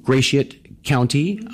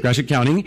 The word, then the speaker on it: Gratiot: The nearest ones here are, there's one in Fremont, and there's one in Gratiot County. I hope I said that wrong. Thank you. Gratiot, Gratiot County.